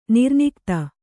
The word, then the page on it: ನಿರ್ನಿಕ್ತ: nirnikta